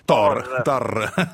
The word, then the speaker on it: Thor: Thor.